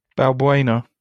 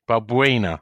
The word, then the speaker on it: Balbuena: Teach me how to say it. – Balbuena. – Balbuena. – You got to break it down. So Bal, like Gareth Bal. Bal. Brain. – Buena. – Like Balbuena. Like you got like a speech impediment when you say brain.